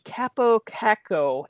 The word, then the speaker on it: Kaapo Kakko: New York Rangers rookie Kaapo Kakko, and I hope I said that right. – Sounds right.